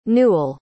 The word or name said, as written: Newell